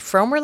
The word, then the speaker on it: Frommer: You pronounced it right. Frommer like Homer.